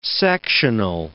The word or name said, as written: sectional